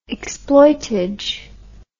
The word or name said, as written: exploitage